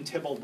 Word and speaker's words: Theobald: spelled Theobald but pronounced Tybalt, who did really the first great edition of Shakespeare.